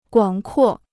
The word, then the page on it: 广阔: guǎng kuò